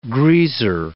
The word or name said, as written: greaser